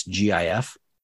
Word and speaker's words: GIF: How do you pronounce GIF? – GIF. – GIF with a hard G.